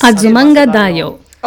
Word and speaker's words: Azumanga Daioh: It's called Azumanga Daioh. Am I saying that correctly? It's Azumanga Daioh. – Okay, yeah. Daioh.